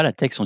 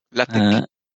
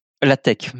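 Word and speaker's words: LaTeX: Enfin, on ne dit pas la texte, on dit... j'oublie pas comment on le prononce, celui-là. – La tech. – La tech, merci. Du Shopify, du Unsplash, UPS, Wikipédia aussi.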